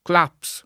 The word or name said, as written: Claps